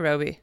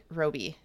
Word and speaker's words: Robie: She was in Twin Peaks and Is it Robbie or Robie? – Robie. Might be Robie.